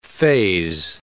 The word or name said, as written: faze